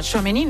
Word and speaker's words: Tchouaméni: El Real Madrid presenta a Tchouaméni, no sé si lo he dicho bien. – Perfectamente. Hombre. Es el segundo fichaje del equipo blanco y el primero en ser presentado, el centrocampista Aurélien Tchouaméni, procedente del Mónaco.